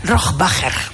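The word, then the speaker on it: Rohrwacher: Rohrbacher.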